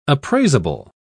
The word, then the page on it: appraisable: British English